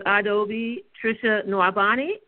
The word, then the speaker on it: Adaobi Tricia Nwaubani: Today I'm speaking with Adaobi Tricia Nwaubani.